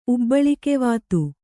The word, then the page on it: ಉಬ್ಬಳಿಕೆವಾತು: kannada